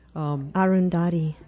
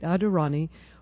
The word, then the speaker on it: Arundhati: And this is featuring Adirondi, I'm not ever sure I'm saying this name right, but I've heard it said right, Arundhati. – You know, I just couldn't do it. I'm looking at it, and I couldn't say it right. Adirondi Roy,